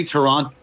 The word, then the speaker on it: Toronto: You say Toronto, right. You don't pronounce the second T in the end of it.